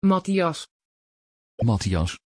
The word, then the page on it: Mattias: Dutch